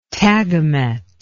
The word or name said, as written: tagamet